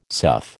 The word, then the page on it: sough: American English